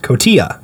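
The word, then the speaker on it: Cotija: Kojita? Am I saying that right, or have I watched too much Dragon Ball? – That looks... You mixed up the order of those letters. Kotia.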